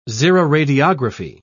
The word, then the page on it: xeroradiography: British English